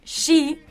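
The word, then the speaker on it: shi (四): Bueno, cuatro es el número evitado en los hospitales de Japón para las habitaciones, porque su pronunciación, shi... Es la misma que la de la palabra muerte.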